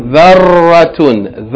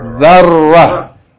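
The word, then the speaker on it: ذَرَّة: Second word has shadda on the ra. So pay attention to emphasizing the ra. Second word. Zarratun. Zarratun. Zarratun. Zarratun. If we stop on this word, we pronounce it how? – Zarra. – ذَرَّ يعني the ta marbuta is always at the end of the word. If you stop on it, we don't pronounce it like ta, we pronounce it like ha.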